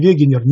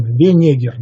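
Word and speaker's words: Wegener: Я считаю произношение Вегенер неправильным, и поэтому говорю через букву «Н» – Венегер, наоборот. На мой взгляд, это более правильно с точки зрения перевода этой немецкой фамилии.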